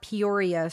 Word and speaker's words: Peoria: And we have CCTV footage showing Lashaya walking down North Peoria Street.